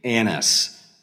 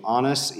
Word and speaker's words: Annas: If I pronounce it like Americans do, it's going to be Annas. But since I've got so much Midwestern in me, I'm too embarrassed to say it like that. So I'm doing more of the Greek pronunciation of Annas, even though that makes me sound like I'm hoity-toity.